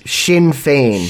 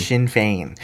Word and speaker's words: Sinn Féin: The pronunciation of the IRA's political arm is Sinn Féin. – Sinn Féin.